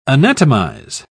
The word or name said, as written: anatomize